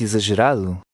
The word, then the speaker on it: exagerado: In number 3, the X is pronounced like a Z. Read number 3. – Leia o número 3. – O filme é muito exagerado. Exagerado.